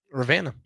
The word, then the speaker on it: Ravenna: It's not. – Ravenna. – Ravenna. – You say it with like the Savannah accent. – Okay. – Ask Ravenna.